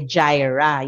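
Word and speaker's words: Jireh: Pag babae, Jira. Yung tamang pronunciation ng Jehovah Jira.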